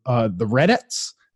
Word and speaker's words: Redettes: I mean, I think I'm saying it right. It's like R-E-D-E-T-T-E-S. – Okay. – So it's not like Reddit, like the...